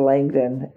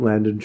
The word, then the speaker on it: Landon: Oh, by the way, guys, I was saying his name wrong. It is Landon, like Michael. It is. I was right. But then I overcorrected into Langdon. It's Landon.